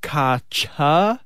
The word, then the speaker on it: focaccia: So this is, if you want to impress your Italian mates, make sure you pronounce focaccia, focaccia. Apparently, some people are pronouncing it fucasia.